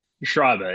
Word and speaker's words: Schreiber: Did I say that right? – Schreiber, it's close. – Schreiber. I should know better because with a name like Yabanowicz, I don't even get people attempting my name.